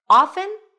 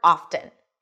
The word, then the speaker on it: often: Often or often? So, obviously, if it's on this list, then both pronunciations are correct. In fact, in Old English, the T was always pronounced often.